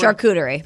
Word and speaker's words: charcuterie: Charcuterie board? – Charcuterie.